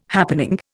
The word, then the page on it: happening: Indian